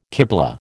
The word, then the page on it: kiblah: American English